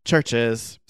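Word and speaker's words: CHVRCHES: But yeah, Churches, pronounced Churches, Scottish synth pop group, been around since like 2013-ish or so, or 2011.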